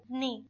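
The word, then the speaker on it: Ni (你): Ni. – Un cuarto acento estupendamente hecho.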